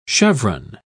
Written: chevron

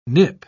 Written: nip

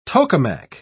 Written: tokamak

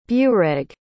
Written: Bureig

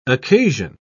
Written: occasion

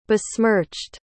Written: besmirched

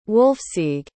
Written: wulfsige

